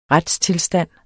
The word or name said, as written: retstilstand